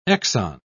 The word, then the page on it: exon: British English